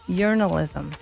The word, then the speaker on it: Urinalism: Urinalism. – Like I yearn. – Ah, that yearn.